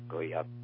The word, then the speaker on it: Go Vap: G-O-V-A-P, but it's pronounced Goyap.